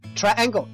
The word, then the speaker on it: triangle: Triangle 就是三角形，注意它读音有点改变哦。